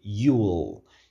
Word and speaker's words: you'll: Aqui a gente tem o you'll, que é o you, you, com will abreviado, né? You apóstrofo LL, a pronúncia é you'll.